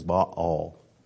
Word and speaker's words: Baal: Okay, the correct pronunciation is Baal. And that's you can split that word right down between the two A's there.